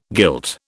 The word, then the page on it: guilt: British English